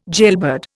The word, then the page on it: jailbird: Indian